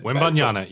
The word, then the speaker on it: Wembanyama: But Wembanyama, yeah.